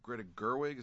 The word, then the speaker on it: Greta Gerwig: That's kind of the way I felt about that film as well, even though it was well-crafted and well-acted by Greta Gerwig. Is that how you pronounce her name? – Correct.